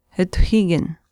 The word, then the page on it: atuhiikin: Chisasibi